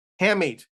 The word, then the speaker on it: hamate: Hamate.